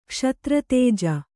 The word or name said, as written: ಕ್ಷಾತ್ರತೇಜ